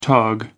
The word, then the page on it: tog: American English